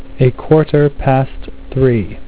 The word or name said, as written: quarter past 3